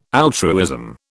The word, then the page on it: altruism: British English